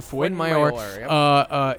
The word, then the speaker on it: Fuenmayor: So, when I look at their defense, which I just described as being mediocre but inopportune, Alejandro Fuenmayor, going to pronounce his name correctly, Fuenmayor, is, I think, a bad center back.